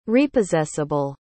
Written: repossessable

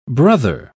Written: brother

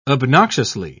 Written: obnoxiously

